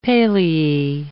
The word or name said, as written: paleae